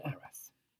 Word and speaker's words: Arras: And just so you know, the town of Arras, A-R-R-A-S, pronounced in English Arras,